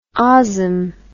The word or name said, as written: osm